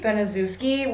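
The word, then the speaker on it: Baniszewski: Beniswitzky? I would be Beniswitzky. Benazuski. – Benazuski? – Benazuski would be close. All right.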